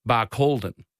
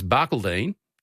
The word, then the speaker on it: Barcaldine: Marie from Wynnum says people often mispronounced Barcaldine as Barcaldine.